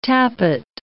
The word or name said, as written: tappet